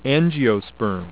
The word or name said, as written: angiosperm